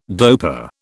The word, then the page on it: dopa: British English